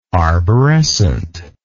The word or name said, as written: arborescently